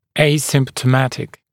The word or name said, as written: asymptomatic